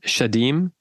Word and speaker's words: Shedim: D-A-E-M-O-N? – It's Shadim. Oh, no. Shadim. S-H-E-D-I-M.